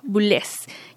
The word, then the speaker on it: Boulez: para pronunciar Pierre Boulet puede ser también Pierre Boulez, ya que vivió bastante tiempo en Alemania.